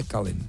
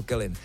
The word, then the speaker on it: Kellyn: Jediná správná výslovnost je, přátelé, Kellyn. Ne Kellyn. Mně tam taky skáče Kellyn, chápu vás, ale je to Kellyn.